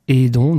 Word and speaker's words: Edons: Donc en cherchant sur un moteur de recherche quelconque Edon, donc Edon ça s'écrit E-D-O-N-S, Vous l'aurez compris, hein, ça se dit pas idon, mais ça se dit bien aidon. Donc c'est un jeu de mots autour du verbe aider.